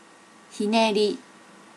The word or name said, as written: hineri